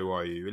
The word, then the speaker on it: Ryu: It's pronounced Ryu, really, but I've got to fucking put my own spin on it.